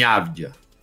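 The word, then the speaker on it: Avdija: Pronúncia correta de Deni Avdia. Acabei de falar. – Deni Avdia.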